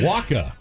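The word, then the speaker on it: Wacha: I'm told, though, it is pronounced Michael Washaw. We've been getting it wrong. – It's not Michael Washaw. – It's Michael Washaw. It's Michael Waka.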